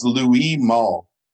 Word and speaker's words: Louis Malle: How is his name pronounced? It's it's Louis Malle. Oh, he's French.